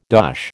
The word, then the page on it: dosh: American English